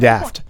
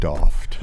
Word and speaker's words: daft: I was trying to be daft. – I think it's pronounced doffed.